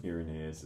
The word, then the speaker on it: Irenaeus: And Thomas, the focus missionary, was talking to me about St. Irenaeus. Mm-hmm. Can someone Is that how it's pronounced? – Irenaeus is what most people say. Yeah. Irenaeus. I always say it wrong, too.